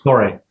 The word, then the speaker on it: Doré: So, are you pronouncing it Doré or Dorgold? – Doré. – Doré, okay, as in.